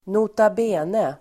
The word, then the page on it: nota bene: ²notab'e:ne